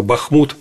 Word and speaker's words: Бахмут: Что касается обстановки на фронтах, то, наверное, сейчас самая горячая точка – это, безусловно, Бахмут. Тут надо ударение ставить на последний слог.